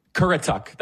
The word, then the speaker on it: Currituck: By the way, there is some, yes, I know I mispronounced the county, okay? I apologize. It is Koretic. Is that right? Oh, my goodness. No, hold on. No. It's Currituck.